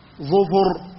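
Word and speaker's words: ظُفُر: ظفر بضم الضاء وضم الفاء، وتنطق أيضا ظفر بضم الضاء وسكون الفاء، وتنطق ظفر بكسر الضاء وسكون الفاء، وتنطق ظفر بكسر الضاء وكسر الفاء.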